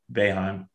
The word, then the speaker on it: Boeheim: Boeheim. – Nice. Good work. I'm proud of you. That's the first time has ever correctly pronounced Jim Boeheim's name on camera.